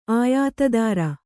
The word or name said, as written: ಆಯುಧಧಾರಿ